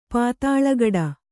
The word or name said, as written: ಪಾತಾಲಗಡ